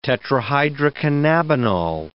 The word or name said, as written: tetrahydrocannabinol